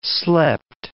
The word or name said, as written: slept